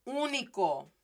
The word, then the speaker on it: único: So this is pronounced as único. Único. Notice it's still the U. It's not a short vowel or anything, but with that accent, I just stress it more, emphasize it more.